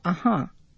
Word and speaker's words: uh-huh: If you listen to uh-huh, the accent is on the second syllable.